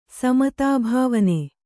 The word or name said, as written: ಸಮತಾಭಾವನೆ